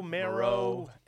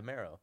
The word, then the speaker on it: Merrow: People were pronouncing it differently, right? – Yeah. – It's Marrow. – M-E-R-R-O-W. – Marrow. – I feel like Marrow. – I guess so, right? – Like bone marrow? – That's where I'm at.